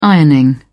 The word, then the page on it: ironing: British English